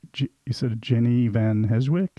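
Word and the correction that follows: Jeanne van Heeswijk: Did I say that right? – Jana van Heswijk.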